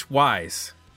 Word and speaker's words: Wyse: But uh, joining us for this episode, uh, a man with a very long and interesting career in music, um, Chris Wyse.